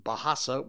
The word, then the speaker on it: Bahasa: And that's the word that I mispronounced earlier. It's Bahasa, which is language.